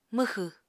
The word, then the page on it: mwxw: Apatani